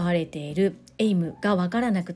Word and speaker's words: AIM: コミュニケーションのフレームワークエイムをご存知でしょうか大変お恥ずかしい話なのですが最初私読み方も分からず AIM とか IM とか読んでいたんですけれどもちゃんと読むと AIM というふうに発音をするそうです